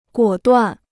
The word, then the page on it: 果断: guǒ duàn